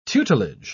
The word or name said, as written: tutelage